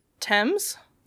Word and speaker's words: Thames: How do you say it? – The Thames? – The Thames, right? – Yeah.